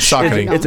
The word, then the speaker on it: Saucony: They interviewed the CEO or one of the top managers, one of the top brass for Saucony. Am I saying that right? – Sure. – Yeah. – It's a Saucony.